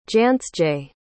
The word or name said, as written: jansje